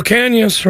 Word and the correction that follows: Ocañas: right there. I'm saying that right. Right, Jude? – Ocañas. – Ah, I'm getting there. Ocañas. Got it.